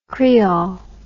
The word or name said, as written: kriol